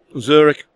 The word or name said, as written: Zurich